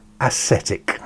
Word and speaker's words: ascetic: When he became a devoted aesthetic. Ascetic. I have to pronounce that properly. Aesthetic is when you appreciate beauty. Ascetic is these people that don't like sensual enjoyment.